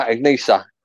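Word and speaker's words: Ichnusa: I can't really pronounce it properly. It's Ignisa. – Peroni? – No, Ignisa. Oh, it's spelled I-C-H-N-U-S.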